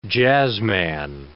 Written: jazzman

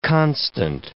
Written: constant

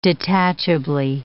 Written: detachably

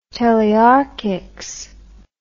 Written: telearchics

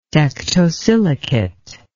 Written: tectosilicate

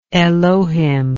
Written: elohim

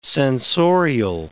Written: sensorial

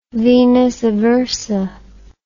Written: venus aversa

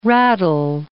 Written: raddle

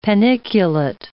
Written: paniculate

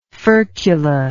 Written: furcular

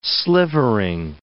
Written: slivering

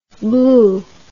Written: bleu

